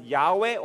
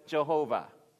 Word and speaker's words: Yahweh: And it's spelled YHWH. In Hebrew, it's just four letters, YHWH. There's no vowel in it. And so people didn't know how to pronounce it. How do you pronounce just the consonants? And so they put vowels, and now they say they will pronounce it Yahweh, or better known as Jehovah. An English translation or English pronunciation of Yahweh would be Jehovah.